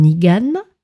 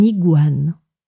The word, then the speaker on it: iguane: Ici, nous allons voir quelques mots qui contiennent la suite de lettres G-U-A. Un iguane. Donc, on ne prononce pas un igane, mais on dit bien un iguane. Autre animal, le jaguar.